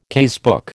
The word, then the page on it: casebook: American English